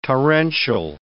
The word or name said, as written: torrential